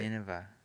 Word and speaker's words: Nineveh: Nineveh.